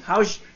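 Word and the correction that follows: Hauge: ¿cómo, cómo, se dice Barak? Hauga, Hauga.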